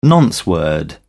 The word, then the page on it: nonce word: British English